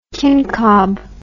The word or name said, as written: kincob